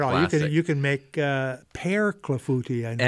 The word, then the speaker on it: clafouti: Clafouti is C-L-A-F-O-U-T-I. – C-L-A-F-O-U-T-I.